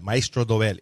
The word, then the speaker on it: Maestro Dobel: Maestro Dobel. That's how you pronounce that. Maestro Dobel, if you know any Spanish.